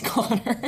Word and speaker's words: Conchobar: Meagan reads aloud on Twitter that the news has broke that my man Louis Tonsils is planning on naming his son Connor, spelled C-O-N-C-H-O-B-A-R, Controbar, pronounced Connor.